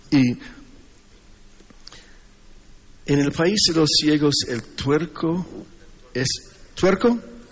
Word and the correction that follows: tuerto: Tuerco. Tuerto. Con T.